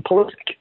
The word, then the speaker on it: Pulisic: And that's how, you know, we tried to get everyone to understand that he pronounces his name Pulisic.